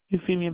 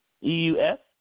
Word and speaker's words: Euphemia: Euphemia Brown, Euphemia.